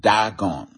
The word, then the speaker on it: Dagon: So they brought the ark of God into the house of Dagon and they set it by Dagon. You know. You can also pronounce that Dagon. And if you put an R there, it will be Dragon, you know.